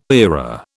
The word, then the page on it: era: British English